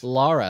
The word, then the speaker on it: Lara: And Lara, I like that you said it right. – Right. – There's people out there that still say Lara Croft, and it makes me want to smack somebody.